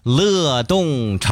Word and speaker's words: 乐: 搜服务号这个地方呢，在这栏里面输入乐动城市，哎，乐动城市，乐动城市，乐是多音字，就乐动，就是音乐的那个乐啊，啊，这个多音字乐也是乐动城市，点了没啊？